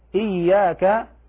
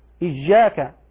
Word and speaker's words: إياك: تجب العناية ببيان رخاوة الياء ليحصل التخلص من شائبة الجيم كما ينطق بعض الأعاجم، وهذا لحن فاحش، فيقرؤون في يقرؤون قوله تعالى إياك إجاك، هذا لحن فاحش.